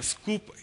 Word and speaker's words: Sqoop: Scoop, here, we didn't spell it wrong. It is spelled in the Hadoop world as S-Q-O-O-P.